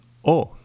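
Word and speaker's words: eau: O, que es una letra así pronunciada, pero que se escribe en tres letras, E, A, U, y como muchos habréis adivinado, quiere decir agua en francés.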